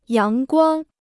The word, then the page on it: 阳光: yáng guāng